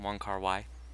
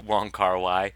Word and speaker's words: Wong Kar Wai: Wong Kar Wai? – Yes, that's his name. – After reading about Wong Kar Wai, I discovered that he doesn't use scripts when he's shooting his movies.